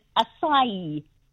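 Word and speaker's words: açaí: But now I know that the correct pronunciation is acai.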